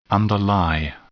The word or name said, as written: underlie